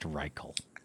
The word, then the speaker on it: Reichl: R-E-I-C-H-L. Okay. I'm going to say it's pronounced Reichel.